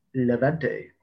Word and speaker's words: Levante: Atletico are currently sitting fourth in La Liga behind Barcelona, Levante. Sorry about the pronunciation. – Levante.